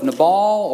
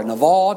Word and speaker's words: Nabal: There's a man by the name of Nabal, or Naval, depending how you pronounce it.